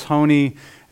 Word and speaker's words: Honi: It takes a real historical figure from the first century BC, a man named Honi, H-O-N-I, I think that's how you say it, Honi.